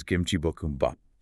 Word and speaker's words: kimchi bokkeumbap: But the core pronunciation to know is the romanized Korean name, which is kimchi bokkeumbap. – Kimchi bokkeumbap, yeah. And breaking that down, kimchi is obvious.